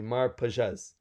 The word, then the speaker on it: Mar Pagès: Mar Pagès. – Yes, correct. As if you were saying it in French.